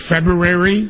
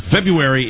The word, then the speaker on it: February: Well, the R is silent. – Well, getting back to my point, yes. – February is known, back east as the doldrums.